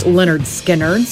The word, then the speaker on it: Lynyrd Skynyrd: It was today in 1973 when Lynyrd Skynyrd, they released their debut studio album, pronounced Lynyrd Skynyrd.